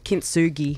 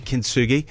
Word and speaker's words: Kintsugi: Kintsugi. – Kintsugi.